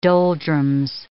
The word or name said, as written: doldrums